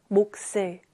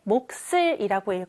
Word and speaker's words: 몫을: 역시 앞에 있는 자음, 기역으로 소리가 나요. 몫을. 그러면 뒤에 있는 자음 시옷은 뒤로 옮겨가서 몫을 이렇게 읽어야 하는데 몫을 몫을 계속 반복해서 읽다 보면 선생님 몫을인 것 같아요. 쌍시옷이요. 그래요. 바로 이렇게 몫을이라고 읽어야 자연스럽습니다.